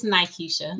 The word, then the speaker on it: Nykisha: It's Nykisha.